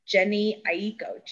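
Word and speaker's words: Jhené Aiko: So for R&B this year, I found recently, and I hope I say her name right, Jenny Aiko, J-H-E-N-E-A-I-K-O.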